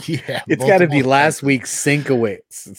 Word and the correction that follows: Sienkiewicz: Oh, you're still not saying it right. – Sienkiewicz. Sienkiewicz. I got it. – There you go. Sienkiewicz.